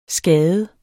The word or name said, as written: skade